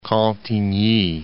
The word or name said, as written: Cantigny